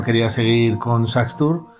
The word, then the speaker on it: Xasthur: En este caso se llaman Saxtur, que se escribe Xasthur.